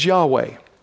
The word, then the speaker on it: Yahweh: And the English equivalent is YHWH. And our best shot at pronunciation is Yahweh.